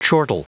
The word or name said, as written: chortle